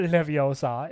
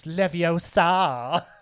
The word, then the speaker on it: Leviosa: It's not Leviosa, it's Leviosa.